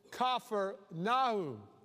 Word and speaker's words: Capernaum: Jesus' headquarters was in a town we pronounce Capernaum. It's a town on the northern shore of the Sea of Galilee. he was going to his headquarters. Now, they pronounce it Capernaum, the village of Nahum. And so, Capernaum.